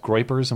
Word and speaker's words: Groypers: Groypers, am I pronouncing that correctly? – You are.